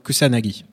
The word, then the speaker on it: Kusanagi: C'est en résumé, en très très résumé, l'histoire dans un futur proche de Major, Motoko Kazunagi, je le dis bien? Pardon. – Kusanagi. – C'est ce que j'ai écrit en plus. Tu sais que c'est ce que j'ai écrit? Ouais. J'ai dit Kazunagi, mais c'est Kusanagi qui a sur marqué.